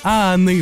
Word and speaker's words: ahanner: Et euh, il existe même, ça je vous en parlerai plus tard, mais le verbe aner aussi. – Ah, ah. OK. – Le H n'est même pas prononcé on dirait que moi je le prononcerais là.